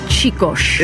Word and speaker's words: Csikos: Okay, I'm going to pronounce it for you. It is C-S-I-K-O-S. Chicos.